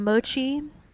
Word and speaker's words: mochi: Then I got some mochi. – Is that how you say it? – Mm-hmm.